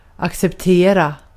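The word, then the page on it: kabul etmek: Turkish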